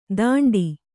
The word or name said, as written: ಥಂಡಿ